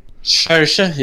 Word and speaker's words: Saoirse: How do you say her name? – Saoirse, yeah.